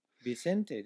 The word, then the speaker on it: Vicente: Joao Vincent de Castro. – Vicente de Castro.